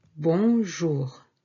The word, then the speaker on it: bonjour: We can also say instead of salut, bonjour. Bonjour. Bon and jour. One word. Bon, nasal, from the nose, and jour. Bon jour.